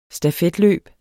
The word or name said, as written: stafetløb